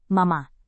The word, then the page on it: mama: Indonesian